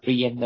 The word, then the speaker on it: Brienz: bên đây nó đọc là Brien, đó là B Lai Boy, BRIENZ, hồ Brien, hai cái hồ đẹp, đẹp hàng đầu thế giới, nó xanh lẹ, đẹp, rộng.